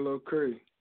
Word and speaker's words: Lil Kree: That's your boy, Lil Cray. – All right, Lil Cray. How you spell that, Cray? – L-I-L-K-R-E-E.